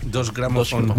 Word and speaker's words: Deutsche Grammophon: Es más, Deutsche Grammophon, este, la, es Deutsche Grammophon, la primera, eh, perdón, ¿cómo se pronuncia correctamente, por favor? – Dos Grammophon.